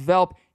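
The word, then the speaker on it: Welp: It has a W on it, but it's pronounced Velp.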